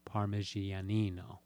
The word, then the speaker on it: Parmigianino: it was again attributed to Parmigianino.